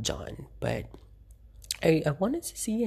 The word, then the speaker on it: Can: So apparently for Turkish, they spell it as C-A-N. My a little bit trivia for everyone. Yes, so C-A-N, if you would look at it. I don't really go into particulars about, but they pronounce it as John. But if you would see like this how it's spelled is C-A-N.